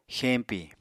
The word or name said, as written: xeñpi